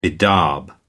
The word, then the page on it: bedaub: American English